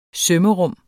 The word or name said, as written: sømrum